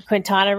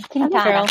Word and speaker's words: Quintana Roo: And it might be Quintana Roo. Am I saying that wrong? – Quintana. – Quintana. – Quintana.